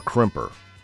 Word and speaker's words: crimper: A cripper. – A cripper or a crimper? – Oh, probably crimper. I'm probably saying it wrong.